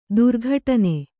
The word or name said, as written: ದುರ್ಘಟನೆ